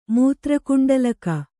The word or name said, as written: ಮೂತ್ರಕುಂಡಲಕ